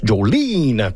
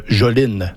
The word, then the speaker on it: Jolene: Et là, je prononce Jolene parce que la chanson en tant que telle s'appelle Jolene, tout simplement.